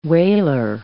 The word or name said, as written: wailer